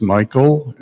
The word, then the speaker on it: Michael: For the record, my name is Michael, M-I-C-H-A-E-L. Last name is Zarum, it's spelled Z with a Z, like a zebra, Z-A-R-U-M.